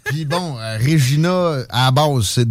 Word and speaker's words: Regina: de la prononciation de Regina qui sonne comme Vagina.